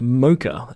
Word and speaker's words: Mocha: It's actually pronounced Mocha, and there's a reason for this. And to my Australian sensibilities, it's really grating.